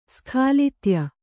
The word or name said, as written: ಸ್ಖಾಲಿತ್ಯ